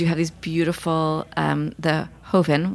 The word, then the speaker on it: Joven: which it's spelled like Joven, all of you people out there, because I which was what I was going to, how I was going to pronounce it until I learned better.